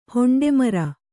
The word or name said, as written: ಹೊಂಡೆಮರ